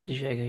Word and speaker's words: De Jager: I mean, like I said, they're getting rid of um, I never know how to pronounce it. De, De Jager, who Jagger, yeah,